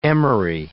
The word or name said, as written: emery